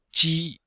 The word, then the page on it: ji: ji1